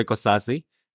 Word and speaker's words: Kosasih: Well, my name is Roy Kosasih.